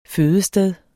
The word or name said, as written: fødested